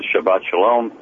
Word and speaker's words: Shabbat Shalom: If I can remember the days of my youth, I don't know if the young lady's still listening, but Shabbat Shalom.